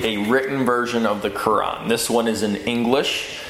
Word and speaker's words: Quran: Quran. And that's, if you want to know how to pronounce that, it's K-O-R-A-N, okay? Quran. It makes it a little easier to pronounce, but it's spelled with a Q.